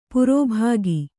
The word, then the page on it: ಪುರ್ಬಿಕ್ಕು: purbikku